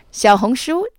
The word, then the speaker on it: Xiaohongshu: Xiaohongshu, that's spelled X-I-A-O-H-O-N-G-S-H-U, NGSHU, literally means Little Red Book in Chinese, although the company's English name is simply Red.